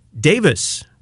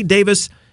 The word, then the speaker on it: Davies: I say Davis, it's spelled Davies, but he's Australian, so maybe like the British, like Ray Davis of the Kinks. It's spelled like Davies, but pronounced Davis.